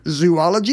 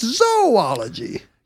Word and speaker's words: zoology: It is not zoology, it's zoology.